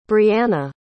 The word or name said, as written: breanna